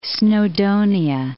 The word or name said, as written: Snowdonia